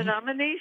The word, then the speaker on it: Menominee: Menominee, sure.